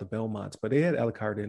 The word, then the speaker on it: Alucard: How do you pronounce it? Alucard? – Yeah, Alucard. Yeah, it's like Dracula backwards.